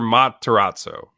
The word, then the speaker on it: Matarazzo: Heather Matarazzo. How do you say it? Heather Matarazzo.